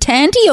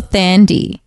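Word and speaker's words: Thandie: Is it Tandy or Thandi? – It could be Tandy. – I think it's Tandy, but yeah. I could also could be very wrong. – But I would say the way I pronounce it first, it's the other way. – Right. – As a general rule.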